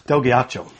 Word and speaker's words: Ghiaccio: And we know this because we actually know one of the actors by name, Dom Delaguido, I think was his name. Let me actually get that correctly. I haven't noted down here. – Yeah. Del Ghiaccio. – Del Ghiaccio, mm-hmm. Yes.